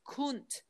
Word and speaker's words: cunt: I mean, you know, the word cunt was actually pronounced kunt, as in kundalini and the kunda gland, which gives it a whole mm-hmm.